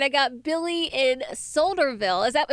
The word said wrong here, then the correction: Sudlersville: Am I saying that right, Billy? Hello, Billy? – No, it's Sudlersville.